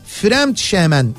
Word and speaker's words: Fremdschämen: Ee, şöyle e, Almanca'da Fremdschämen diye okunuyormuş. Evet Fremd, Fremdschämen. – Ee, başkası adına utanmak aslında başkası adına utanmak kadar da uzun bir şey sayılır zaten Fremdschämen.